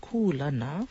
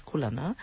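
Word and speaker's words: kūlana: In this particular olelo no eo, it's kulana, so spelled K-U with the kahako or the makron over that U, L-A-N-A, and kulana, pronounced kulana versus kulana.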